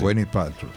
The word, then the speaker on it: Gwyneth Paltrow: Y la actriz, si mal no recuerdo, no sé cómo se pronuncia bien ahí, Gwyneth Paltrow, algo así. – Gwyneth Paltrow, sí.